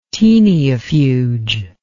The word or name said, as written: taeniafuge